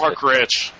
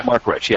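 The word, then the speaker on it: Marc Rich: This sounds strangely like the Mark Ritchie story. If, am I getting his name right, or whatever the guy was? – Mark Rich. Yeah. – Mark Rich, yeah.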